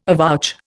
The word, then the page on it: avouch: Indian